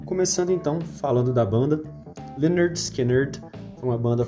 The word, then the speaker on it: Lynyrd Skynyrd: da banda que tem um nome esquisito, Leonard Skinner, cheio de Ys, mas a pronúncia é simples, assim como a música, Simple Man, Leonard Skinner.